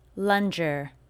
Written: Lunger

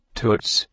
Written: Toots

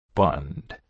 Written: Bund

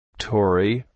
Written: Tori

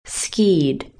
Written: skied

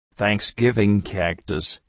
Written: Tori

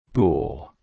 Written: Boule

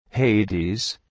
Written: Hades

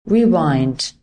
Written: Rewind

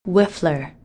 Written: Whiffler

